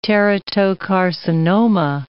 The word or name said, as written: teratocarcinoma